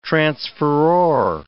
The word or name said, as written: transferor